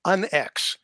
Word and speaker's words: UNX: So you've had a couple of changes lately, and you're on Unix. How do you say that? Is that, did I pronounce that correctly? – No, it's actually UNX. – UNX. – Yeah, okay. just the letters UNX, which really kind of stands for unexplained.